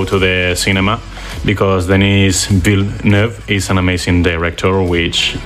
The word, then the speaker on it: Villeneuve: Villeneuve, more in a French way.